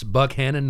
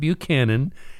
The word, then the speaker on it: Buchanan: It's Buckhannon, not Buchanan.